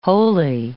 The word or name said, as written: wholly